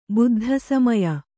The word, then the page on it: ಬುದ್ಧಸಮಯ: buddha samaya; Kannada